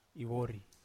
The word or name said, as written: vori